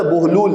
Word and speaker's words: بہلول: جبکہ اصل لفظ ہے بہلول، بر وزن ہے صندوق. صندوق کا جو وزن ہے وہ بہلول کا وزن ہے.